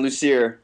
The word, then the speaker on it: Lucier: Lucier.